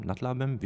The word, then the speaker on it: nad Labem: Nad Labem, wie spricht man das aus? – Nad Labem.